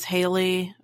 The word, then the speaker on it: Haley: Haley's Comet is actually pronounced Halley's Comet because it has two L's. It's it's it's pronounced like Valley Halley. I'm Haley.